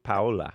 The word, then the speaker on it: Paola: P-A-O-L-A. – So you don't know for sure? – I pronounce it Paula.